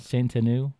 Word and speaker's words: Saint Anne: Saint Anne.